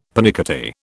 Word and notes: pernickety: British English

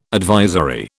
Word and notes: advisory: British English

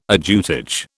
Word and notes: ajutage: British English

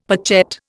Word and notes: pochette: Indian

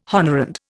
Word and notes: honorand: Indian

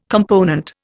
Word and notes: component: Indian

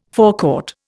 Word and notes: forecourt: Indian

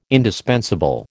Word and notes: indispensable: American English

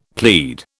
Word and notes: plead: British English

